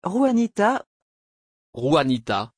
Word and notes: juanita: French

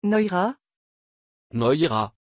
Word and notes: Noira: French

